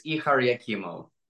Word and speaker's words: Ihar Yakimau: So it's Ihar Yakimau.